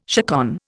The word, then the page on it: chaconne: Indian